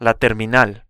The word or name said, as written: la terminal